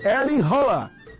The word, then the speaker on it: Erling Haaland: A pronúncia correta, Rafinha, é Erling Haaland. Ele mesmo se apresenta assim.